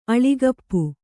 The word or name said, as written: ಅಳಿಗಪ್ಪು